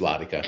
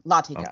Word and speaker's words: Latika: I said Latika too. It's Latika. – And that's us putting our Western accent on a word. Latika.